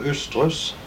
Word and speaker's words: Østerhus: Den heter Østerhus, men vi kaller den for Østrus. Vi kaller den Østrus. – Ja. Det er eller uttaler det Østrus.